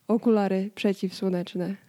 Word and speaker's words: okulary przeciwsłoneczne: Yeah, in English, it's sunglasses, but in Polish, you have to say okulary przeciwsłoneczne.